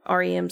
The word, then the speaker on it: REM: Like when you go into REM sleep, I said that right. I'm always afraid I'm going to say it like the band.